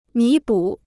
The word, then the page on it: 弥补: mí bǔ